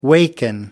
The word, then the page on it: waken: American English